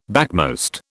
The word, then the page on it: backmost: British English